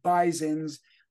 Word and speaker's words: Bisons: War Memorial Stadium, the former home of the Buffalo Bisons, pronounced with a Z as opposed to owls with a Z.